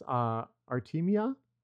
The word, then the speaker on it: Artemia: And I hope I say this right, they're from the genus Artemia.